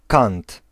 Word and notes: edge: /ɛdʒ/

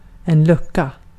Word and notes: lucka: /ˈlɵ̀ka/

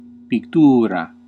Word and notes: painting: /ˈpeɪn.tɪŋ/

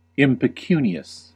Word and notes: impecunious: /ˌɪm.pəˈkjuː.ni.əs/; /ˌɪm.pɪˈkjuː.ni.əs/

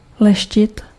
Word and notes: polish: /ˈpɑl.ɪʃ/; American English

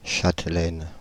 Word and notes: châtelaines: /ʃat.lɛn/

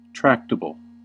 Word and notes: tractable: /ˈtɹæk.tə.bəl/

